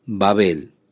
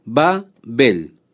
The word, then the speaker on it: Babel: Por esto fue llamado el nombre de ella, Babel. O podemos pronunciar también Babel, como usted prefiera, como la palabra bárbaro.